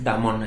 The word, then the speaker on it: Damon: Es, Damon, pero es Damon, así como se escribe.